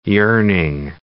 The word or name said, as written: yearning